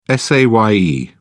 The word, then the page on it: saye: British English